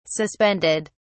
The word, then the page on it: suspended: British English